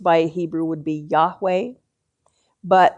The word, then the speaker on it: Yahweh: And more often now, you will hear English-speaking people pronouncing this word Yahweh.